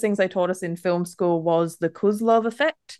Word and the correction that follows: Kuleshov: Yeah, I know it as the Kuleshov effect. – Yes, probably the better way to pronounce it.